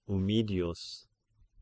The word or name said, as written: Ummidius